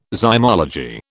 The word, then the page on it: zymology: British English